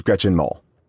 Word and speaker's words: Gretchen Mol: I just pressed the YouTube pronunciation of Gretchen's name. And it is Gretchen Mol.